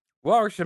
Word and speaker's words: Warwickshire: Adric and cunt, dyslexic cunt, invade Warwickshire. Warwickshire? I think that's the place. – Not bad. – Warwickshire. – What? Warwickshire, mate. – That's how you say it over there. Warwickshire.